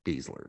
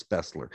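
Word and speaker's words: Besler: Of course, we always made fun of his name because the way it's spelled, it's not Beasler, it's Bessler. Anyhow, in German it is.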